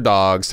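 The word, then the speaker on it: Dogue: You say it like Vogue. – Yeah. – Like Vogue, Megan, Miss Dog.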